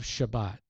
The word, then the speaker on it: Shabbat: If you wrote the word Sabbath down and handed it to an Israeli and said, read that, they would pronounce it Shabbat. TH is pronounced like a T. And a single S is pronounced like sh.